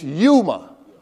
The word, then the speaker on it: Yuma: Now, one thing sister told me, she said, you pronouncing human. It's Yuma.